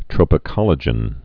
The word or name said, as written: tropocollagens